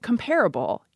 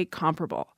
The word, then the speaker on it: comparable: If you take compare and add able, you don't get comparable. You're supposed to say comparable. The American Heritage Dictionary asked the usage panel in 2002 about these two pronunciations, and 70% rejected comparable. Though the editors of the dictionary note that pronunciation, comparable, is very common and is probably on its way in.